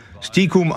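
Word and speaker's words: stiekum: Und noch was, Steakum oder Stikum. Stikum ausgesprochen, das Wort kommt aus dem Jüdischen und bedeutet insgeheim und leise.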